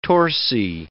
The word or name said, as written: torsi